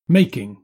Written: making

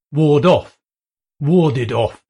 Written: ward off

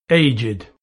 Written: aged

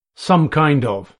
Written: some kind of

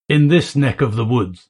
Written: in this neck of the woods

